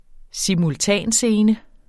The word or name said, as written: simultanscene